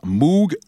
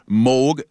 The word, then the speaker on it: Moog: utiliser le synthétiseur Moog ou Moog selon la bonne prononciation.